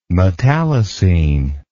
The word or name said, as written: metallocene